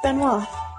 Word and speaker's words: Benoit: And Valerie let us know that the name is French and pronounced Benoit. – Benoit. I knew Benoit sounded wrong.